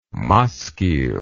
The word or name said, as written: maskil